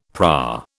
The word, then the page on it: PRA: British English